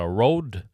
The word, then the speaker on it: Røde: Det er røde, men det er fra Australia, så de, ut, de uttaler det Road.